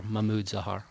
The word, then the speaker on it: Mahmoud Zahar: Mahmoud Zahar.